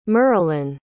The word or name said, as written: merelan